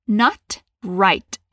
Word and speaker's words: not right: você vai ver que tem dois T's aqui que a gente acaba não falando. Not right. Isso acontece por causa do sotaque. Tem lugares em que o T é mais pronunciado mesmo, mas no geral, nos Estados Unidos não se pronuncia não.